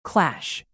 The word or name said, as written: clash